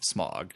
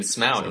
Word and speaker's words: Smaug: I know, before you were a frequent member on the show, our first episode, we talked about The Desolation of Smaug. – You mean Smaug, so by the movie pronunciation.